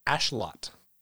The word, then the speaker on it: Axolotl: It's pronounced Ashlot. – Neutral. I kind of... – The L is silent at the end. – Okay. – And the X is a sh sound.